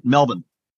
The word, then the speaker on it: Melbourne: So Florida, it's Melbourne, and Australia, it's Melbourne. – Melbourne. – Get rid of the R.